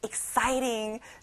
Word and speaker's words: exciting: 싸이에 세게 한번 발음을 해보는 거예요. Exciting.